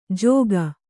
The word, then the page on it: ಜೋಗ: jōga